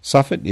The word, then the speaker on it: Suffet: Suffet is spelled S U F F E T.